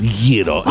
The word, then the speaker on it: gyro: So for anybody who does the gyro, gyro, Yeah, it's properly pronounced Yiddo. – Wow. – In Greek language.